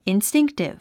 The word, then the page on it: instinctive: instíŋktiv; インスティンクティブ